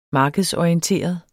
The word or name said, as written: markedsorienteret